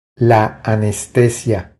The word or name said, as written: La anestesia